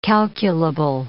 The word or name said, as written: calculable